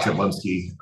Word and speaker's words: Jedlinski: and nobody can pronounce Jablinski, and they always leave out the L.